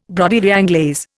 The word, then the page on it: broderie anglaise: Indian